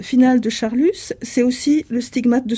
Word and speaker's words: Charlus: Donc, il faut certainement, si on se la joue Guermantes, il faut certainement prononcer Charlus.